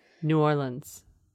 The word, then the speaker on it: New Orleans: Just think L-I-N-S. New Orleans.